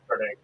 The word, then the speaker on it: Carnegie: And I had only heard differences of it, like, traveling around in that area when I realized, they pronounce it differently. – Mike's setup, though, I mean, he completely described it as a outsider, I guess. – Now, here's a question. Pennsylvania is a pretty large state. – Yeah. – Like, if you go, like, further, east in Pennsylvania, do they, like, pronounce it Carnegie? – I asked one of the guys I work with in Philly. He drove it up and looked at his whole life. He said, Carnegie.